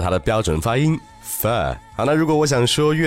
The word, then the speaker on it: pho: 但是我想说的并不是越南河粉的味道如何，而是这个越南河粉的英文一定要去说对，它的拼写是 P H O。所以说，如果看拼写的话，这个词很容易让我们读成 pho，对不对？但是事实上，它的正确的发音是 f，f。